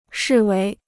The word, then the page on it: 视为: shì wéi